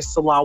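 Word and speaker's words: Nselaa: Sila Ward. And you also have to be able to spell my name because it's spelled a little differently. It's N-S-E-L-A-A. The N is silent to be able to look me up.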